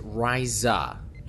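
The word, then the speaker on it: Risa: It is pronounced Ryza and Rycian. The S sounds like a Z.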